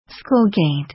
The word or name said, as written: school gate